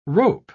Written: rope

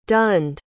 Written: dunned

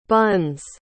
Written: Bunns

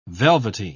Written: velvety

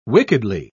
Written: wickedly

